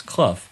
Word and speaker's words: Clough: As it turns out, the pronunciation for his name is Clough, but you would have no particular reason to think that way based on English phonetic spelling.